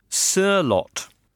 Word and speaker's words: Sørloth: Roy Hodgson at Palace, who can now count on the services of Sørloth. So that's thanks to Morten Nilsson. He says, in Norwegian, it's pronounced very much like rapper Sir Mix-a-Lot, without the mixer, obviously.